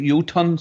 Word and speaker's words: Jotun: And you pronounce it Jotun, so I don't pronounce the J. J-O-T-U-N. Jotun.